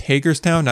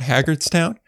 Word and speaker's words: Hagerstown: It's pronounced Hagerstown, not Haggardstown. It's kind of like Sammy Hagar.